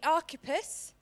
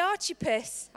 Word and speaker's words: Archippus: His name is pronounced two ways. It could be Archippus, or it can be Archippus. That's the way we spell it. That's the way I'm going to pronounce it. The reason being is, if anybody knows me, I love chips. And I thought, why not? Let's use the Archippus.